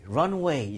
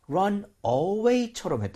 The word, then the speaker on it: runway: 우리 runway 있잖아요. – 예. – 그 발음을 좀 잘못하셨던 것 같거든요. – 아, 그랬나요? – 약간 run away처럼 했던 것 같아요.